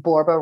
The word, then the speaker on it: Borba: My website's Michelle Borba. I'm a 1L Michelle. My last name, Borba, rhymes with Zorba.